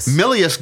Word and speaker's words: Milius: Milius got his hands on the script.